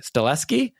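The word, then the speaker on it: Stahelski: But also Sifu is continuing this version of the adaptation, is working with how do you say Chad's last name, Stileski? Yes.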